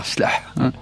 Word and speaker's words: Chleuh: Vous le prononcez bien en plus, hein. – Tribu Chleuh. Chleuh.